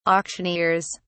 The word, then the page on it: auctioneers: British English